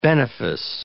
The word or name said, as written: benefice